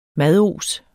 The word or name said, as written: mados